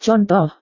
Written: contoh

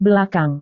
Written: belakang

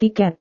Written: tiket

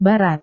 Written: barat

